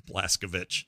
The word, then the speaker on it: Blazkowicz: Blazkowicz. – Oh, it's Witz. – Yeah, yeah, that is how they say it. – Like, I think, like, if you just say it's Witch, but it's Witz.